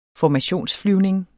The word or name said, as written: formationsflyvning